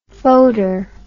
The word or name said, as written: Fodor